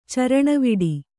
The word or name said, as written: ಚರಣವಿಡಿ